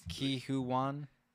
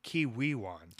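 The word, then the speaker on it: Ke Huy Quan: Ki-Hoo-Wan? – I think it's Ki-Wee-Wan. – Ki-Wee-Wan. Okay. – Yeah. Ki-Wee-Wan. – Thank you. – Is what I think it's pronounced, because he gave a speech at the Golden Globes.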